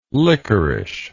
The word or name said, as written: liquorish